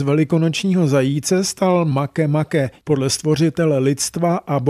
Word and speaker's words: Makemake: Jmenuje se Makemake a má 20. narozeniny. Ano, Makemake, nikoli make-make, jak by k anglické výslovnosti mohl napsaný název svádět.